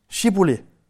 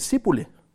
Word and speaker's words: Shibboleth: Svarede han nej, sagde de til ham, sig Shibboleth. Hvis han så sagde Sibole, fordi han ikke kunne udtale ordet rigtigt, greb de ham og huggede hovedet af ham ved Jordans vadesteder.